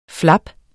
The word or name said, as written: flap